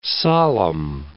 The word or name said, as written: solemn